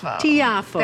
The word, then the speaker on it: Tiafoe: Tiafoe. – Tiafoe.